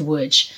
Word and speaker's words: Łódź: Łódź, L-O-D-Z. It's pronounced Łódź.